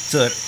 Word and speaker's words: Thor: Thor. Tengo que aprender a pronunciarlo bien. Pero es Thor. No es like Thor. Es Thor.